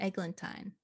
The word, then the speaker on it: Eglantine: So Angela Lansbury, I don't think I can say it properly. Eglantine. – That's it. – Eglantine.